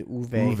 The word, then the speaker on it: Uwe: Also, the New York Post is suing Uwe Boll, right? That's how you say his name? – Uwe, yes. It's Uwe.